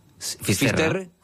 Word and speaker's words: Finisterre: Entonces llegó a Finisterre, ¿se dice así? – Finisterre.